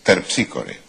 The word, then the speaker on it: Terpsícore: Esther Píscore, dijo. – No dije Esther. – ¿Cómo que no dije? – Dije, es Terpsícore. – Claro. Esther Píscore. Esa es la pronunciación griega, claro.